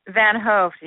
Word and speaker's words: Van Hoft: Van Hoft, yeah, the O is long. It's a Dutch thing.